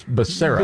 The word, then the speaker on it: Becerra: But it's not Chud, it's Becerra. – Becerra, right. And see, even I'm pronouncing it wrong now.